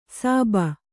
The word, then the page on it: ಸಾಬ: sāba